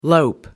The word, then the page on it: lope: American English